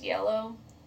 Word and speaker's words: Iello: Yeah, is that how it's pronounced? Yellow? I-E-L-L-O? – Oh! – L-O, that particular company?